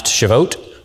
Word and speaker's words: Shavuot: You may have heard the Jewish word Shavuot, perhaps pronounced Shavuot, right? Shavuot, Shavuot, Feast of Weeks.